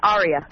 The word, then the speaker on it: Aria: It's Aria. – Aria. Well, like an operatic aria.